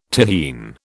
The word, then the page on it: Tyrrhene: British English